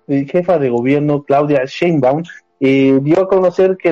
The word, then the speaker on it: Sheinbaum: La jefa de gobierno, Claudia Chainbaum, ah, no, Sheinbaum, ¿verdad? ¿O cómo se dice? – Sheinbaum, Sheinbaum, por favor.